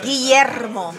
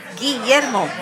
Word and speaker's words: Guillermo: What's the correct pronunciation? – Guillermo. – Oh, Christ. Oh, we can say that. First of all, it starts with a fucking G. – Guillermo.